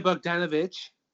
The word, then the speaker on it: Bogdanovich: Peter Bogdanovich.